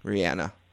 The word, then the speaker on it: Reanna: R-E-A-N-N-A. – Oh, man. That's interesting. – Just Anna with a re at the beginning. Rihanna.